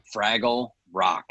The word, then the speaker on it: Fraggle: Fraggle Rock. – Like fragile? And like spelled like fragile? F-R-A-G-G-L-E. Fraggle.